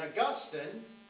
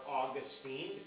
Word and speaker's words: Augustine: And Augustine, or Augustine, depending on how you want to pronounce his name, who lived from 354 to 430 AD.